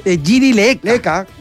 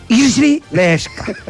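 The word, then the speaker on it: Jiří Lehečka: Claro, porque nosotros le decíamos Giri Leka. – Leka como suena. – Pero, exactamente, como se escribe. – Lejeska. – Pero es Giri Leska.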